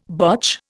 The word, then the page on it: botch: Indian